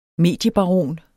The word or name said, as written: mediebaron